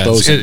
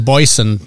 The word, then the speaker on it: Bosun: B-O-S-U-N. – Yeah, Boson. It does actually have the pronunciation right on the can. – Oh, that says Boyson to me.